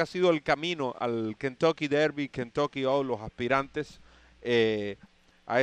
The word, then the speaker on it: Kentucky Derby: válidos para la clasificación al Kentucky Derby.